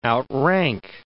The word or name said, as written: outrank